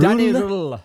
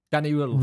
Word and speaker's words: Röhl: Danny Röhl. – Röhl? – Röhl. – Is it Röhl? – It's like Hull. It's like Hull. Röhl. Danny Röhl.